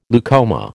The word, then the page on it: leucoma: American English